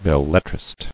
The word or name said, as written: Belletristic